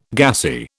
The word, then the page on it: gassy: British English